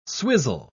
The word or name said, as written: swizzle